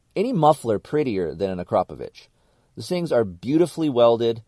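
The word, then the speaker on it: Akrapovič: That's how you say it, okay? Akrapovic. It's not Akrapovic. It's Akrapovic.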